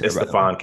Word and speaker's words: Stephon: I called him Stephen Castle. – It's Stephon Castle.